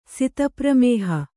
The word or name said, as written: ಸಿತಪ್ರಮೇಹ